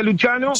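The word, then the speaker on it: Lucciano: Luciano, lo escriben con doble C, sí, se pronuncia Luciano, sí. Como se pronuncia en italiano.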